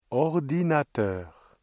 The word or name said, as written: ordinateur